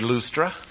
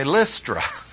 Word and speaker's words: Lystra: You don't want to say Lustra. Say Lystra. The upsilon is a Y in English.